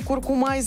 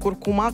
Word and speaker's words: куркума: Норма постановки ударения в слове «куркума» изменилась. Большой словарь русского языка закрепил вариант «куркума» как основной, тогда как раньше. Единственно верным был вариант «куркума», сообщили РИА Новости в Институте русского языка имени Виноградова РАН.